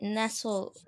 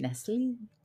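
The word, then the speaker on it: Nestlé: If you want a fun drink, get Nestle Water Splash. – Nestle. – Why is it that? – Because it's got the little over the E that makes it a French Nestle.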